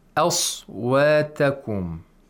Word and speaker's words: أصواتكم: Aswatakum. A, we pronounce the fatha with tarqiq. – Thinness. – And the sod with tafkhim or thickness. Aswatakum.